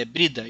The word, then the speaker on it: Brida: É, é, é isso, é, eu falei errado. É, não é Breda, é Brida, isso.